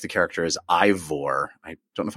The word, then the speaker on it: Eivor: E-I-V-O-R. – I would say Eivor. – Eivor. – There's a guy Eivor at work whose name is Einar. So I'd say Eivor.